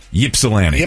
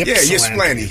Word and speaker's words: Ypsilanti: Ypsilanti. – Ypsilanti.